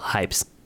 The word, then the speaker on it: HYBS: 而我觉得这首 Ride 在一开始就给你一种复古慵懒的感觉，但是在背景的底衬上却是搭配着现代的效果器哦，这也可以说是 Hypes 这个团体的招牌特色吧。那我今天要来跟大家介绍的这个团体叫做 Hypes。 HYBS 应该有蛮多可能在听他们音乐的人，甚至都还不知道这个名字怎么念吧？大家都会称 HYBS HYBS。